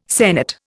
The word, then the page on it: sennit: Indian